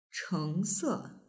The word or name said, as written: chengse